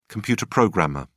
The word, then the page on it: computer programmer: British English